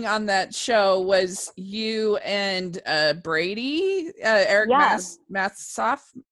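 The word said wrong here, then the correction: Martsolf: Martsoff. Yeah, sorry. – Everyone mispronounces his name.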